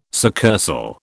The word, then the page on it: succursal: British English